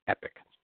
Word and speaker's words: epoch: It looks like it's spelled epoch, but it's pronounced epic.